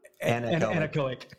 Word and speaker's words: anechoic: So they find this little mountain, which is like a, oh, I'm going to mispronounce it, anaeric chamber? – Anechoic. – Yeah, there you go. Thank you.